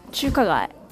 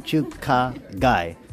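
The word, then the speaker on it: Chukagai (中華街): Chukagai. – Chukaga, like chu. Chu. But same pronunciation. Chukagai.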